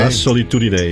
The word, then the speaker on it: La Solitudine: La Solitudine is het. – Ja, zo. Okay, hoor je het nou? – Zo moet je het uitspreken. Ja, dat is Italiaans.